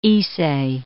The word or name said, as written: Ise Bay